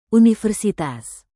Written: universitas

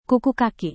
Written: kuku kaki

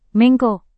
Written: menko

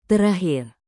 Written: terakhir

